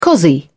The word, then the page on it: cossie: British English